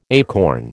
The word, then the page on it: acorn: American English